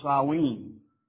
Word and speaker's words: Samhain: Fallen within this category is a word that looks like Samhain, but says it's pronounced Samhain.